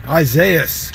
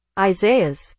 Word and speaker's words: Isaias: So if you didn't know how to pronounce it, this was mispronounced so much in 2020 that I just wanted to let everyone know, just as it sounds out, because it's in Spanish, Isaias is going to be one that we're going to have to say a lot of this next week.